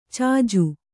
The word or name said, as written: ಚೆಜ್ಜ